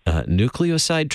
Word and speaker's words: nucleoside: Nucleoside treatment, did I get that right? – That's, to my knowledge, you pronounced it pretty good.